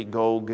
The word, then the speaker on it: Gog: I think the Hebrew is actually a long O. It should be Gog and Magog, but that just sounds weird. We don't say it that way very often at all.